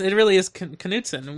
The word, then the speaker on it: Knutson: It really is Knutson, which is how you would say that name in America. But I'm just asking why it's not silent like any other word that might start with K.